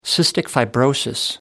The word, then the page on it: cystic fibrosis: American English